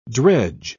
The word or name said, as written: dredge